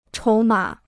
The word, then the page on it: 筹码: chóu mǎ